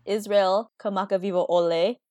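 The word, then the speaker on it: Israel Kamakaviva'ole (Israel Kamakawiwoʻole): Hawaii's own artist, Israel Kamakaviva'ole.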